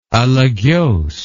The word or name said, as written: alagez